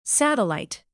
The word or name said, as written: satellite